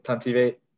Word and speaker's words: Tuntivate: How did I say, did I say it right? – Yeah, that was basically it. Tuntivate.